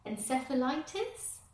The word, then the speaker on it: encephalitis: I don't know how to say this, encephalitis? – I think you said it right.